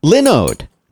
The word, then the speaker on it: Linode: Linode. Because it's Linus. Pronounced Linux like Linus Torvalds.